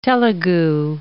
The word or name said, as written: Telugu